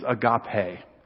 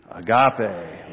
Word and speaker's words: agape: But the two Greek words for love that we're going to learn today, the first one is agape. I've spelled it out phonetically so we can say it together. Ready? 1, 2, 3. Agape.